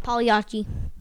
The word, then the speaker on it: Pagliacci: Pagliacci. – Wow. I also love his pronunciation of Pagliacci. He pronounces it perfectly, right? – The G is silent. The G is silent.